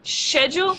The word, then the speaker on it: schedule: In Scottish or British English, would say schedule, right? With a sh sound at the beginning? – Uh-huh.